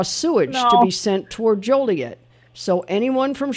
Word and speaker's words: Joliet: Joliet? – Yes. Yes. Joliet is correct.